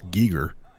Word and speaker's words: Giger: Geiger? – Yeah, accurate. Oh, yeah. – Fun fact. It's actually pronounced Giger.